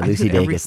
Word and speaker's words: Lucy Dacus: I mean, I got Boy Genius, Better Oblivion Community Center, and Lukey Dacus. I said that right. No, I didn't. Lucy. – Yeah, Lucy Dacus.